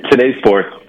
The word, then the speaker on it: T-Day Sports: Is it T-Day or Today Sports? – It is Today Sports, but it's spelled T-Day Sports.